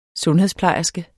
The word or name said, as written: sundhedsplejerske